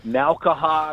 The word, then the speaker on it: Nowkhah: You know, Nowkhah. I mean, people try to pronounce every single letter of the last name. If I didn't know me, I wouldn't know how to say it either.